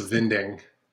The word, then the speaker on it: Winding: I tend to say with a V, like Nicholas Vinding.